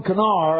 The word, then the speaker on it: Conchobhair: Dun, spelled E-O-C-H-A-I-L, but it's O'Keel. Dun, Canar. How in the world do you get Dun Canar out of C-O-N-C-H-O-B-H-A-I-R?